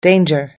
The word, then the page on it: danger: us-english